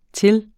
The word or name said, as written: till